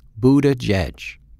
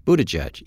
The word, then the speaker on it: Buttigieg: And while we were preparing for the interview, the hardest question my producers tackled was how to pronounce his name. But in the months since, we've all learned to say Pete Buttigieg. Buttigieg.